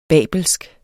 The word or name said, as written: babelsk